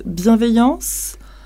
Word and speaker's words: bienveillance: Yeah, there is, I never find the right word in English, but we call it bienveillance in French, and it's, a behave of looking after and looking for people.